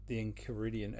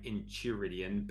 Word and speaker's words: Enchiridion: Probably incorrectly, but I was saying Enchiridion, but it probably is K, because in Greek it would be a K. So you're probably right. – Enchiridion? – Yeah.